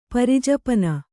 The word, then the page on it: ಪರಿಜಪನ: pari japana